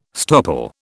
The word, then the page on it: stopple: British English